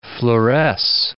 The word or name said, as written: fluoresce